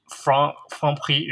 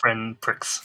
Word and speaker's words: Franprix: I mean, with most French words, you don't pronounce the last letter. – Mm-hmm. – So like some of the last letters like X, for example. – Yeah. – So actually Franprix is the way to pronounce it. – Mm-hmm. – Yeah, the N, you notice I didn't pronounce the N as well. So, Franprix. – Okay. – Yeah. – Fantastic. – It's not friend pricks.